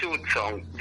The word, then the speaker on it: Hudson: Bueno, voy a discrepar con usted con la pronunciación del apellido. – Hudson. – Para mí es Hudson. Yo, como decía Sarmiento, como se escribe lo pronuncio, ¿no? – O sea que usted es Guillermo Enrique Hudson.